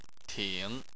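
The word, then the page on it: ting: tone 3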